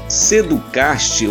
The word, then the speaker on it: Seducast: Lembrando que Seducast, ou melhor, Seducast se escreve S E D U C A S T. Seducast se escreve como Seducast, tá bom? E esse T é mudo.